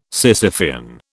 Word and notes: Sisyphean: British English

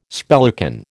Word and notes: spellican: American English

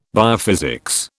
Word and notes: biophysics: British English

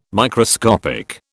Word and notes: Microscopic: British English

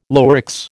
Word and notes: Lurex: American English

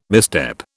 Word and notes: misstep: British English

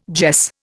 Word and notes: jess: Indian

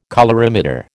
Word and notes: colorimeter: American English

